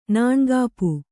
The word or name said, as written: ನಾಣ್ಗಾಪು